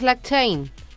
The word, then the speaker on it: blockchain: Oye, cuéntanos, ¿qué es blockchain? ¿Cómo, cómo lo pronuncias? ¿Cómo lo dices? – Blockchain, tal cual, lo has dicho muy bien.